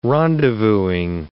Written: rendezvousing

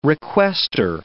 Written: requestor

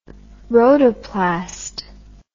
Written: rhodoplast

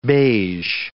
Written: beige

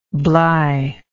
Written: bly

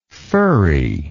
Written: firry

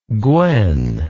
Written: gwen

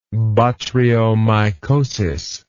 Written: botryomycotic

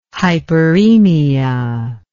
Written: hyperaemia

